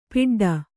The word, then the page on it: ಪಿಡ್ಡ: Kannada